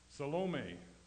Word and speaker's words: Salome: You know, the E is pronounced, it is long. Fortunately, the O in her name is long also, or her name would be Salami, which would be a terrible thing, eh? But it's not. Her name is Salome, Joanna, the wife of Herod Steward Cusa.